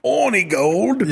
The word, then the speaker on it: Hornigold: In English, it's horny gold. Yeah. The British pronunciation is horny gold. – Or orangey gold. – Yeah, the British pronunciation is orny gold.